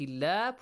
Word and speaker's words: الله: آيات الله. Produce the sound of لام in the word Allah with a light sound. Lightly pronounce it.